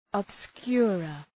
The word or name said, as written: obscurer